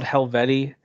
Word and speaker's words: Helvetii: It's called Helveti. It's spelled really annoyingly. It's Helvetii. I'm like, all right, Wii.